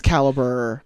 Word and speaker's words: Calibre: It's spelled like the weird European way. It's like Calibre. – Okay. Calibre. – Yeah. But it's Caliber.